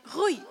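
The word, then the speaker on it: Rui: Pisze się R, U, I. E, I e, litery R, które są na początku wyrazu albo które są podwójne, jak w słowie gitara, wymawia się jak takie ch. No więc mówimy rój. Nawet czasami jeszcze bardziej na H.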